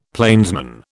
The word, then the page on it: plainsman: British English